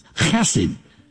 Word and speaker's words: chesed: It's a Hebrew word translated kindness, chesed. If you say it right, something lands on the back of the neck of the person in front of you.